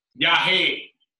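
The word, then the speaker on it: yagé: How do I say Yage? – Yahe. – Yeah, see, I would have got that wrong.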